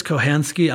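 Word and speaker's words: Kochanski: Kochansky or Kohansky? – I think it's Kohansky.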